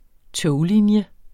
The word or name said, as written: toglinje